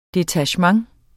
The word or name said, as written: DETACHEMENTERNES